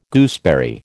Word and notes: gooseberry: American English

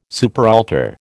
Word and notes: superaltar: American English

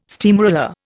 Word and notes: steamroller: Indian

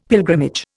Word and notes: pilgrimage: Indian

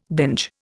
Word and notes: binge: Indian

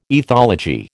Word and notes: ethology: American English

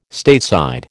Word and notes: Stateside: American English

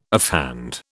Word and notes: offhand: British English

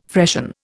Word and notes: freshen: Indian

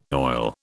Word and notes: oil: British English

